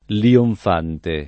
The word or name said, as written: lionfante